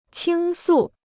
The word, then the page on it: 倾诉: qīng sù